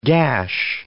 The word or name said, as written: gash